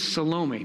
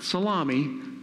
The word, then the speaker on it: Salome: Her name is Salome, not Salami. Salome. I used to pronounce it Salami till I got corrected.